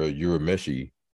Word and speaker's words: Urameshi: I listened to your Urameshi. – Mm-hmm. I don't mm-hmm. know if I'm saying it correctly. – Yeah, yeah, that's correct.